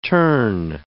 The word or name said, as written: tern